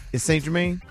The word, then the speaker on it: Saint-Germain: Saint-Germain.